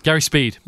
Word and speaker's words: Speed: And he's pulling this little minnow team that had been set up all those years ago by Spieth to Gary Spieth, rest in peace. Wait, did I get the name right? – Gary Speed, pretty much. – Speed. Spieth is a motorcycle racer.